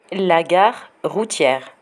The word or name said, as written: la gare routiere